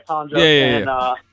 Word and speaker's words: Colin Jost: So it was Colin Jost. – Okay. – Am I saying it right? Yeah, Colin Jost and Michael Che.